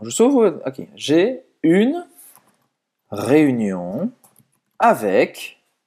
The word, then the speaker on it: réunion: Então presta atenção nessa pronúncia, tá? Réunion, réunion. Esse U faz a boca do U e fala aí.